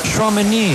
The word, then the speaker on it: Tchouaméni: Tchouaméni. – ¿Tchouaméni? – Tchouaméni.